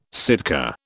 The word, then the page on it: Sitka: British English